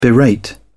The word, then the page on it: berate: British English